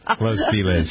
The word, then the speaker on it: Los Feliz: Got to say it like a local, buddy. – I know. Los Feliz. – It's like Houston and Houston.